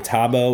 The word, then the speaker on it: Thabo: It is under the name Tabo, which is spelled T-H-A-B-O.